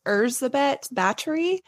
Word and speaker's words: Erzsébet Báthory: Oh, by the way, before we get into this, I should say, I'm confident her name's probably not pronounced Elizabeth Bathory, but that's probably the best you're going to get out of me. – Yeah, it is Hungarian. So her name is Erzsébet Báthory de Exed, which I'm...